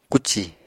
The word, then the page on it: kúcì: Apatani